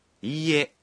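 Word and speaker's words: ইয়ে (いいえ): যখন আপনি বলবেন ইয়ে তখন এর ই অংশটি একমাত্রা লম্বা করে উচ্চারণ করতে হবে।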